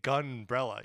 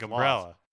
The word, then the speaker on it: Gunbrella: because when you say umbrella, the emphasis is on the second syllable, right? You don't emphasize the of umbrella. You say umbrella. But when you say Gunbrella, you really have to say Gunbrella. You have to emphasize the first syllable.